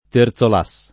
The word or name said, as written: Terzolas